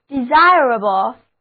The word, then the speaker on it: desirable: 最后我们在 the desire 后面把 e 去掉，加上表示能够的 able 形容词后缀，那么单词 desirable D-E-S-I-R-A-B-L-E desirable 就表示值得向往的、可取的、有利的。重音放在第二个音节上，单词就读作 desirable。